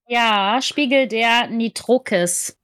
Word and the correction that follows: Nitokris: Fast, Nitokris.